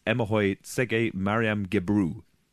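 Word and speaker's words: Emahoy Tsege Mariam Gebrou: I'm going to murder the pronunciation here, but this is Emohoy Tsege Mariam Gebru.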